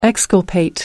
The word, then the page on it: exculpate: American English